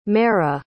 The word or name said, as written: maira